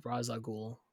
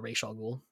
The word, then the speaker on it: Ra's al Ghul: Bruce, now in his old age, very old age, goes to find Ra's al Ghul, or Ra's al Ghul, depending on how you want to pronounce it,